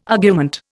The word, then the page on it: argument: Indian